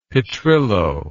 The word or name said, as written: petrillo